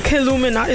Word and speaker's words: Killuminati: So is Illuminati or Killuminati? – Killuminati. Is it a K? – Killuminati.